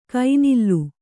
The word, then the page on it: ಕೈನಿಲ್ಲು: Kannada